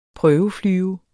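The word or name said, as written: PRØVEFLYVER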